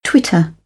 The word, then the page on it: twitter: British English